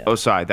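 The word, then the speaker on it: Ossai: Osai, that's how you say it.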